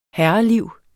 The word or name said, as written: herreliv